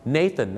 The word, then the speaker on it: Nathan: ฝากไว้หนึ่งชื่อครับ Nathan นะครับไม่ใช่นาธานครับแล้วก็อย่าลืมนะ